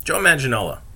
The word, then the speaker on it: Joe Manganiello: Joe Maganella. I always fuck his name up. Sorry. You know, the big burly dude who is from True Blood. Played the vampire. Joe Manganiello. There we go. Knew I'd get his name right if I just powered through.